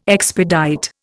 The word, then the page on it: expedite: Indian